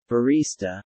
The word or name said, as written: Barista